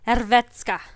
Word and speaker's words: Hrvatska: Hrvatska.